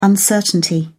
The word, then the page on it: uncertainty: British English